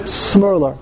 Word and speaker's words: Shmerler: Shmerler. – Okay, he says here, Mareinu Rosh HaYeshiva Rav Chaim Shmerler.